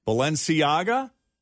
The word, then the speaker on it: Balenciaga: Balenciaga? – That was right.